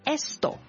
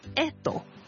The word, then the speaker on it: esto: 如果呢我想指呢、這个呢样，咁西班牙文正確或者係清楚嘅读音呢会係 esto。是啦，咁但系咧，古巴人咧就会省略咗个 S 音咧，读成 edo。